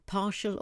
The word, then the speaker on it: partial: Partial or incomplete. Partial is spelled P-A-R-T-I-A-L. Partial. It sounds like an S-H word, but it isn't.